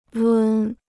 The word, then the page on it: 抡: lún